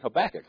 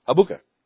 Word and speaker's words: Habakkuk: I realized he was saying Habakkuk. That's how he pronounced it. Habuka.